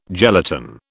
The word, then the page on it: gelatin: British English